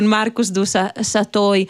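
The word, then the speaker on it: Marcus du Sautoy: C'era pure un'anteprima con la matematica della creatività, come i numeri danno forma al mondo, con Marcus du Satoy, e non so se la pronuncia è quella giusta. – Sì, è giustissima.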